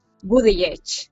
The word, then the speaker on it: Buttigieg: No, de hecho tuvieron que aprenderlo y es lo que se comentaba cuando su candidatura se hizo pública, cómo hay que llamarle, y es Buttigieg.